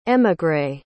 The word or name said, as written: émigré